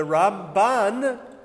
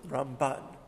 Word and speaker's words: Ramban: The Ramban, Rabbi Moshe ben Nachman, we put the stress at the end of the word, Ramban.